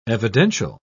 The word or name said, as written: evidential